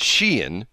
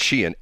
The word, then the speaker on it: Shein: But she said, first off, it's pronounced Shein. That's the way she says it's officially pronounced. Shein, S-H-E, like she in, you know, like she's in, like it's, you know, like trendy clothing, stuff like that.